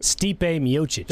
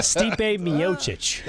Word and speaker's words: Stipe Miocic: Stipe Miocic.